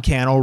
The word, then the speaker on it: Cannell: Sean Cannell rhymes with channel. First name Sean, S-E-A-N. Last name Cannell, C-A-N-N-E-L-L.